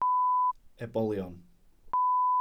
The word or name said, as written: ebolion